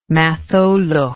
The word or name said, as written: Matholwch